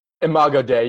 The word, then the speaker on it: Imago Dei: But let's riff on that that theme of Imago Dei. Is that am I saying that right, boys? – Imago Dei, yeah.